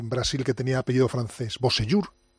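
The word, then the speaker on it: Beausejour: Bosellur era. – Beau con B. Sejour. – Jean Bosellur, este. – Bosellur.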